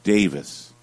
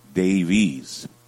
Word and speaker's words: Davies: In the UK, they pronounce his name Davis. In the US, we pronounce it Davies.